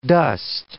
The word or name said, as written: dust